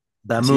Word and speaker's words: d'Amour: Es de 1978 y es de The Police, su álbum debut, Outlandos de Amour. Sí lo busqué la pronunciación tal cual en francés, así se dice. Digo, no faltará quien diga, así no se pronuncia, pero bueno, sí. – D'Amour.